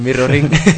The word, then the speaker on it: MRRORNG: La palabra es. Bueno, no, lo voy a pronunciar en modo paleto, es mirroring. lo que pasa es que hemos quitado las is y por eso es como MRR y luego tres is.